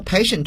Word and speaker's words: patient: 好了，我们都知道 patient 这个词呢，从发音上看呢，它就不是英语中的本族词，所以说这个词我们今天要区别一下它的来源，它来自于 the Latin language，拉丁语。在拉丁语当中呢，ti 往往要读作 sh， patient。